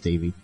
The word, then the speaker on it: Devi: It's spelled D-E-V-I, but it's pronounced Davey.